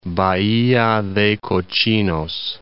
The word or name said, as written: Bahia de Cochinos